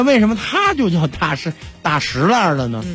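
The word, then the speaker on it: 大栅栏: 双儿化音，中间轻声一笔带过，所以就叫大石栏。 – 哦，哎，这个栅呢有两个读音，咱们查这个字典，一个叫栅，一个是山，一个读音念山。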